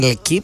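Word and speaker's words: L'Équipe: Ναι, τώρα βγήκε ας πούμε στο ότι και η Λεκύπ, γιατί ναι, έτσι προφέρεται, όχι Λεκύπ, είναι η Λεκύπ.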